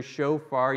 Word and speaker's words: shofar: But here in the Hebrew, shofar, you've probably heard, is how you pronounce the Hebrew word.